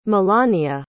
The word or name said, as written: milania